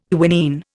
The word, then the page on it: quinine: Indian